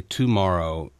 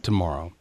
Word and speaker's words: tomorrow: Well, tomorrow is, I think, to go ahead and say tomorrow, accenting the T-O, I think, is really not the best pronunciation. I think it's tomorrow.